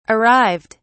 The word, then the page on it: arrived: British English